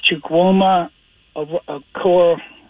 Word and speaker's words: Chukwuma Okorafor: You know, it's Chukwuma Okorafor.